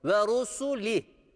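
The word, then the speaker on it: ve rusuli (وَرُسُلِهِ): Bu harfin harekesi fetha değildir. Bu harfin harekesi ötredir. Ve ru, ve rusu, ve rusuli denmesi gerekiyor. Burası da sat harfi gibi okunmayacak. Şu ra harfine de fetha vermeyeceğiz. Ve rusuli diye okunuyor.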